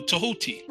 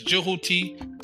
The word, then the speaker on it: Tehuti: Now, the word thought is also believed to stem from the name Thoth, the Greek pronunciation for the comedic name Jehuti or Tehuti. Now, who is Tehuti? Tehuti is known as the deity of wisdom in ancient Kemet. Tehuti sometimes is also pronounced as Jehuti, Tahuti, Jehuti, Tetu, and Thoth is also interestingly known, also can be spelled T-H-O-T-H, and also T-H-O-T, which is thought.